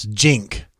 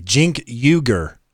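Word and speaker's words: Cenk Uygur: It's Jink. Sorry. Jink Uyghur. – Okay. – Jink Uyghur. Yeah. – Okay. – From him. That's from him, by the way. Verified source. – Oh, verified source. He says how to pronounce it. – He says, my name is pronounced Jink, like short for Jenkins. Uyghur. Uyghur.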